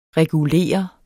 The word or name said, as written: regulere